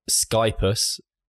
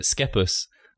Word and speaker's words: Schepis: And on this episode, I am happy to have Jacob Schepis.